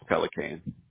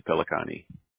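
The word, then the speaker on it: Pellicane: How do you pronounce that? I want to make sure I don't torture that one there. Pelicane? – In Texas, it's Pelicane. In New York, it's Pelicani. – Pelicani.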